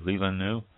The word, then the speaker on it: Leelanau: It is also, there is the Lilanua, or Lilaneo, I'm not sure if I pronounced this right or not, L-E-E-L-A-N-A-U, Lilanu, Lilanu, AVA.